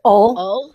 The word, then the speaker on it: Hull: Hull, and you're not supposed to pronounce the H. You're supposed to say Ol.